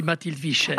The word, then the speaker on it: Mathilde Vischer: Sì, si può pronunciarlo in vari modi perché è romanda di origini basilesi, ecco. Ma qui dicono tutti Mathilde Vischer.